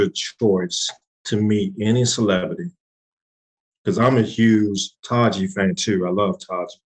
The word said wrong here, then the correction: Taraji: Not Taji. What's, how you pronounce her name? – Taraji. Taraji. – Taraji.